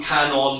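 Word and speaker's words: Kannon: the term Canon, the Japanese pronunciation of Guanyin, is how this particular Buddhist figure is known.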